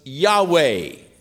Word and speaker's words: Yahweh: Others believe that the actual pronunciation was Yahweh. The vowels were a and a E, a Yahweh pronunciation to his name.